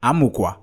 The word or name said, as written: AMUKWA